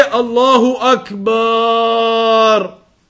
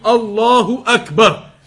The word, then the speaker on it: Allahu Akbar: Don't extend the ba. So don't say Allahu Akbar. That is incorrect as well. When you extend it in that way, the pronunciation of it, it changes the meanings. So you don't say Allahu Akbar, and neither do you say Allahu Akbar. You don't extend it in that way because the meanings can be changed. So it must be pronounced properly and accurately, Allahu Akbar.